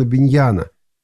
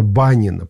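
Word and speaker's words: Bunyan: Похожий прием мы находим, например, в известной книге Джона Биньяна или Джона Баннина, по-разному его произносят, «Путешествие пилигрима», называется эта книга, или «Путь паломника».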